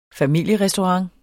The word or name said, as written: familierestaurant